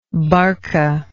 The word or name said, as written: barcan